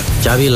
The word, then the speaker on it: Xavi: Xavi. – Yeah, Xavi like chocolate.